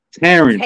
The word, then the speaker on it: Terrence: I like saying Turns. But they'd be like, huh? Excuse me? I'd be like, Turns. They'd be like, huh? I'd be like, Terrence.